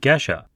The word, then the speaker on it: Gesha: He very politely corrects me on the pronunciation, calling it geisha.